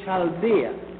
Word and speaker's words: Chaldea: Abraham was born in the city of Ur in Chaldea. It is spelled U-R, Ur, and Chaldea is spelled C-H-A-L-D-E-A.